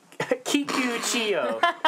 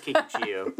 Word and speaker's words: Kikuchiyo: Wait, was that his name? – Yeah, that was Kikuchiyo? – Yep. – I did start with a K. Yeah. – It is Kikuchiyo.